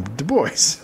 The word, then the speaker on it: Du Bois: Because Du Bois pronounces his name Du Bois.